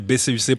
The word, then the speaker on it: BCUC: Il faut, peut-être préciser pour les gens qui nous écoutent que c'est BCUC prononcé à l'anglais. BCUC, voilà.